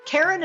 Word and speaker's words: Karen: Karen is spelled the normal way, no goofy Y's or Q's, K-A-R-E-N, and Kataline is spelled K-A-T-A-L-I-N-E.